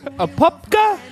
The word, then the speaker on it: Apopka: According to family and friends, Honorato, 30, lived in Apopka. I hope I said that right. – Apopka, Florida, and managed several restaurants in the Orlando area.